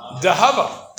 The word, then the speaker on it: dahava: How do you say gold in Aramaic? Dahava, because what do you take? You take Zahav, the Zion becomes a Dalit, and you have an Aleph there.